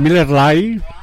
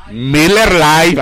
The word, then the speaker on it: Miller Lite: ¿Se dice High Life o High Life o Miller o cómo se pronuncia? Esta Drinky Drinky, esta Kawasaki, esta esta chapeadita, ¿cómo se dice? – Pues ahí nomás dígale a Miller Lite. – Miller Lite. Ay, estoy aprendiendo inglés, hasta me siento que ando por allá en los Jornar States.